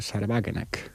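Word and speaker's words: Sahra Wagenknecht: Porque hay, para mí, el experimento de partido más interesante que se está dando en la Unión Europea, por cierto, también putinista, es el de Sarwagenek, o como se diga en alemán, ¿no? – Está bien pronunciado, ¿eh? – Sí.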